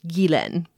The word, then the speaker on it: Ghislaine: The closest I can suggest is Guilin, where the first syllable is pronounced like the Indian butter, and the second is pronounced, well, like len. The emphasis is on the second syllable.